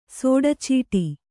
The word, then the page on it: ಸೋಡಚೀಟಿ: sōḍacīṭi